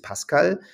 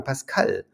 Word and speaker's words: Pascal: Das Deutsche ist Pascal und, und die Franzosen sagen Pascal. Ja, wir sagen Isabelle und der Franzose sagt Isabelle. Der, der, der wartet ein bisschen, bis er die Betonung macht, weil er ne, die Kraft nach hinten raus nochmal verwendet.